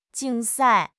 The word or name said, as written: 竞赛